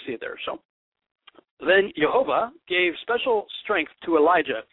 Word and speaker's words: Jehovah: which I believe is pronounced Jehovah. Some say Jehovah, some say Yahuwah, some say Yahweh.